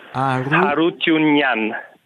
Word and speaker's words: Harutyunyan: Harutyunyan. Tutti i cognomi, tutti i nostri vari cognomi armeni finiscono in Yan e si pronunciano con l'accento finale in An.